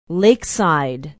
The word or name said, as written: lakeside